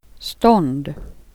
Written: marknads

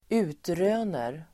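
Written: utröner

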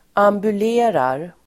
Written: ambulerar

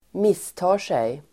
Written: misstar sig

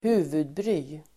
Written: huvudbry

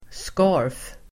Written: skarf

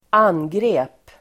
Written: angrep

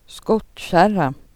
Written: skottkärror

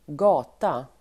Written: gata

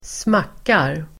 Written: smackar

